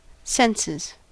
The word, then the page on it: senses: American English